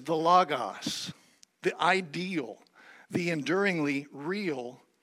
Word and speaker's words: logos: Now, the word that Socrates and Plato used to describe this ideal form from which all reproductions were made, that word is logos, or you may pronounce it logos, and it's translated Word.